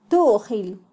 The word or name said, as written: tujil